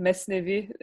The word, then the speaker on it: Mesnevi: Yeah, I think I'm going to say the Turkish pronunciation, Mesnevi, is his main work.